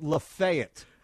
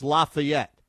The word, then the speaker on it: Lafayette: There is a place in Tennessee called Lafayette. No, it's called Lafayette. Everywhere else in the planet, it's pronounced Lafayette.